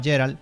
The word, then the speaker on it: Geralt: Sí, bueno, lo primero y más importante es que The Witcher 3 me ha enseñado a pronunciar bien Geralt. De hecho, hay un detalle en el juego que un NPC lo llama Geralt, y él dice, no, es Geralt. – Espera, tú eres el tocahuevos que siempre ha dicho que en España se pronuncia como tal como se lee. Tú deberías decir Geralt o Herald. – Geralt. – Geralt. Y yo rectifico.